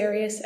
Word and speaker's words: Arias: I've heard it pronounced about 7 different ways. I say Arias, as does the rest of my family.